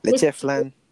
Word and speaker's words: leche flan: Is it leche? – Leche. – Leche flan.